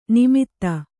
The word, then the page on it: ನಿಮಿತ್ತ: nimitta